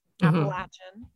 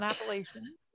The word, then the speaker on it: Appalachian: Appalachian, not Appalachian. Gotta say it right.